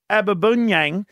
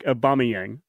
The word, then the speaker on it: Aubameyang: Pierre Aubameyang. he kicked the two goals, Jim. – Aubameyang. – That's him. – Pierre Patrick Aubameyang.